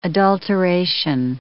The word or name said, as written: adulteration